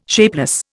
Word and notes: shapeless: Indian

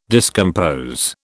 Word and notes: discompose: British English

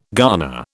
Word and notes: garner: British English

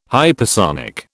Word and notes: hypersonic: British English

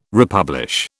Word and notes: republish: British English

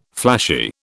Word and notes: flashy: British English

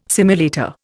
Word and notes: simulator: Indian

